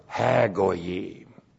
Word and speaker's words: ha-goyim: When you put the definite article, ha-goyim. There's a definite article. Ha. G, long O, Y, long I, M. Ha-goyim.